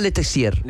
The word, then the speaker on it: Letessier: François Letessier. – Letessier. – Entiendo que en francés las consonantes finales no se pronuncian, no se nombran. – El ER se pronuncia E.